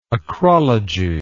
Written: acrology

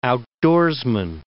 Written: outdoorsman

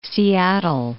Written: Seattle

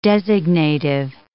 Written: designative

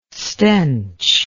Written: stench

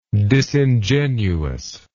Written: disingenuousness

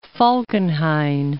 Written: Falkenhayn

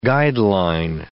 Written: guideline

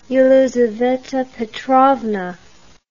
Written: Yelizaveta Petrovna

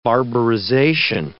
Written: barbarization

